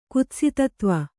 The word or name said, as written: ಕುತ್ಸಿತತ್ವ